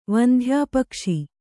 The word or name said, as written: ವಂಧ್ಯಾಪಕ್ಷಿ